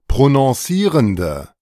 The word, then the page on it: prononcierende: German